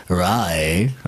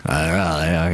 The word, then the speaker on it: Raleigh: Die Band kommt aus Relate, North Carolina. Du kannst das natürlich viel besser aussprechen als ich, aber. – Das heißt Raleigh. – Raleigh, okay.